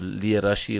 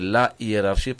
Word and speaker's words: hiérarchie: C'est vrai que beaucoup disent l'hiérarchie, il faut respecter l'hiérarchie, mais on dit il faut respecter la hiérarchie parce que le H de hiérarchie est aspiré, ce n'est pas un H muet.